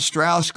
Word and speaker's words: Strauss: And Strauss goes out of his way to say his name is pronounced Strauss because he comes from the South and he's an assimilated Jew.